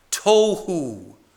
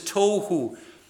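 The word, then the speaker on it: tohu (תהו): The first one is pronounced tohu. Tohu. That's not some bizarre food that you get at a health store. Okay, but it's pronounced similarly, but it is tohu.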